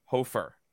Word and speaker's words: Hofer: So let's get to our prospect of the week, and that is Joel Hofer of the – did I say that right? – Yes.